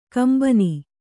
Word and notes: ಕೆಂಬೊನ್: kembon

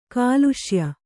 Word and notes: ಕೊಲೆಸಾಯ್: kole sāy

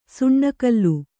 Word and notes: ಸುಣ್ಣಕಲ್ಲು: suṇṇa kallu

